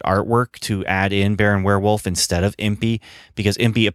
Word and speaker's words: Weirwulf: Now, by that time, they had changed the name to Baron Werewolf, but it's spelled W-E-I-R-W-U-L-F, Baron Werewolf's Haunted Library.